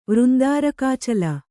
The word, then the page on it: ವೃಂದಾರಕಾಚಲ: kannada